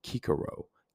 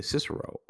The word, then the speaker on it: Cicero: or the name Kikero, Kikeronis, meaning eh, Cicero, or you know, Cicero, if you want to pronounce it correctly, the great Roman orator.